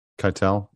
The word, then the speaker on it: Keitel: Keitel.